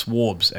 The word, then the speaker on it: Swabs: Fishtails and Swarthy Songs for Swabs. – It's Swarbs, actually. – Swarbs? – I just got corrected on that. – It's supposed to rhyme with Borbs.